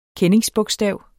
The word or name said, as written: kendingsbogstav